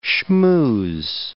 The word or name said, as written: shmooze